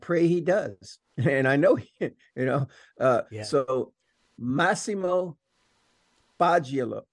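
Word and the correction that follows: Faggioli: what's the pronunciation? – Fagioli.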